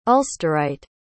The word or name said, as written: ulsterite